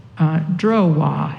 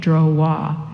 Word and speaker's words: drowa: And the Tibetan word for sentient being, that's what I was, where I left off, was drowa, and that's spelled D as in David, R-O-W-A, drowa, and that means those who go, those who move, those who go. Drowa, dro means to go, and wa is one who goes.